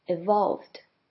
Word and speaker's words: evolve: 那第一个字母 e 念成 e 的声音。接下来 vo 的 o 跟刚刚讲过 possibility 的 o 一样发成 r 的声音。 evolved.